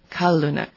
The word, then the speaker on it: Kalunuk: Kalunuk. – Okay, The stress goes on the first syllable, which is contrary to how English-speaking people would normally say it.